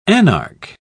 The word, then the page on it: anarch: British English